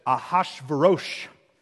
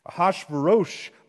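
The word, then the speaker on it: Achashverosh: Now, in the days of Ahasuerus, if you want to pronounce it in Hebrew because you're a nerd, it goes like this, Ahasuerosh. Ahasuerosh.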